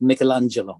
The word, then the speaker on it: Michelangelo: So I actually call it Michelangelo. Mm-hmm. Americans call it Michelangelo. Mm-hmm. But you'll catch me saying Project Michelangelo, because that's the Italian pronunciation.